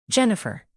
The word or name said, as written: jennifer